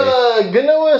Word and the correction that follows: quinoa: seed Triscuits, are they? – Quinoa. – Whatever seed. – It's called, I don't know. – It's pronounced quinoa. – Quinoa.